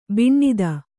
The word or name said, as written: ಬಿಣ್ಣಿತು